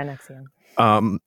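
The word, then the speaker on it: NXIVM: like Nexium. Am I saying that correctly? – Yeah, Nexium.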